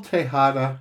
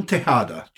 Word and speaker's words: Tejada: Could you spell Tejada? – T-E-J-A-D-A. The J is pronounced like an H.